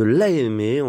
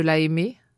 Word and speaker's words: Lahaymeix: La Allez, je le dis bien cette fois-ci, L-A-H-A-Y-M-E-I-X, dans le département de la Meuse. Situez-nous ce, village de l'AME.